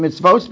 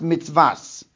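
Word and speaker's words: mitzvos (מצות): Now, I pronounced it in mitzvos because that's how it's punctuated. And when the Baal Kori reads it, he's going to read it with the word by pronouncing it as mitzvos. Nevertheless, it's not written mem tzadi vav vav tuf. It's written with only one vav. It could be pronounced mitzvas.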